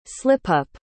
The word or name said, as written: slipup